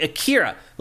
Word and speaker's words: Akira: I'm like, Akira, like the anime.